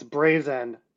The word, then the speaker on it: Brazen: Yeah, I mean, just for clarification on pronunciation, it's Brazen, but great question.